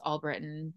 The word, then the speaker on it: Albritton: Last name's Albritton.